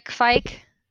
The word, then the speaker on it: kveik: Like people, uh, you can maybe find a fike. – Yeah. – Fike, did not, I say that right? Yeah, you should find some kveik uh, mead, some kveik ciders on the market.